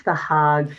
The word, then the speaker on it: Den Haag: Can I just ask, do you pronounce it the Hague or the Haag? I should know from my Dutch background, of course. – Well, I think it's the Haag, but please, someone will come and tell me I'm wrong.